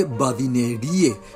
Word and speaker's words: badinerie: Badinerie se escribe badinerie y solemos pronunciar, castellanizado, badinerie en español.